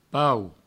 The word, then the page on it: pauc: pau; provencal